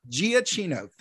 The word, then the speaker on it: Giacchino: Michael, I always mispronounce it. Giacchino. Giacchino, thank you.